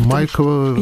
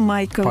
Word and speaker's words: Майкова: Я правильно делаю ударение? Майкова? – Да, правильно. Вы прекрасно. – Майкова. – Именно Майкова.